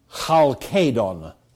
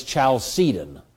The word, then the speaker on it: Chalcedon: In a town called Chalcedon, which nobody says. The K went into Latin as a soft C, and so it's Chalcedon, or Chalcedon, or whatever you hear it called on the radio these days.